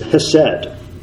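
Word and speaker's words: chesed: It's spelled with a C, but the C is silent. Or actually, in Hebrew, it's not silent, but it's pronounced almost like hesed.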